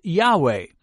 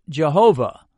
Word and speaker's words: Yahweh: and which we normally pronounce Yahweh, but in the past it was often pronounced Jehovah.